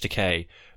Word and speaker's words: Takei: I'm pretty sure it's Takei because... – It is Takei, to rhyme with toupee.